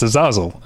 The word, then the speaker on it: Azazel: because they get introduced and they're there and it's Azazel. Not that I knew how to say it before, but that's what it is, Azazel Jacobs.